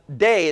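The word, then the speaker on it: day (δεῖ): It's that Greek word, delta, epsilon, iota, which is pronounced day.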